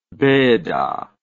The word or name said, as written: benda